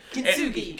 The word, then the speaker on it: Kintsugi: I think I'm saying that correctly. – Yeah, Kintsugi. – Kintsugi, right. Yeah.